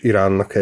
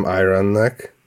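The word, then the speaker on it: Iran: hogy az Iránt azt angolul is Iránnak ejtik, és nem Irannek, ahogy azt szeretné ejteni egyébként az angol,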